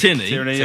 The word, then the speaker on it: Tierney: Is it Tierney? How we pronounce Tierney. – Things? – Tierney, yeah.